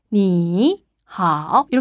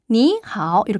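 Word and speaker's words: 니하오 (你好): 그래서 원래는 니하오 이렇게 하면 발음이 힘들어요. 삼성이 원래 자기 혼자 하기도 벅찬 발음이기 때문에 이성 니하오 이렇게 바꾸어 발음해요.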